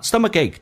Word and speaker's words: stomachache: 那么 stomachache 这个单词，第一个 k 可以浊化成 g 这个发音 stomachache，最后一个 k 可以不用发音，但气流阻塞一下。